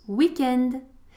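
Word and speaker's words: week-end: Donc on prononce weekend.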